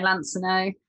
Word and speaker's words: Lansinoh: Right, yeah, Lansinoh.